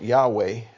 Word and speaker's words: Yahweh: We pronounce it Yahweh, but in Hebrew, the original Hebrew, they didn't have any vowels.